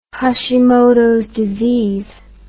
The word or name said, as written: hashimotos disease